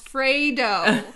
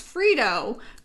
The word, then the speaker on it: Fredo: So, you know, people were like, it's pronounced Fredo. Although there was different ones. – Yes. – Stephanie said it's pronounced Fredo. Carmela said it's Fredo.